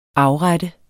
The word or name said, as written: afrette